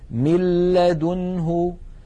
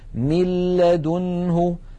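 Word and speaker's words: من لدنه: مثال إدغام النون الساكنة في اللام: مِلَّ لَدُنْهُ مِلَّ. لاحظوا مِل من الميم إلى اللام مباشرة، وننطق لاما مشددة ولم يبق من الغنة شيء. مِلَّ لَدُنْهُ.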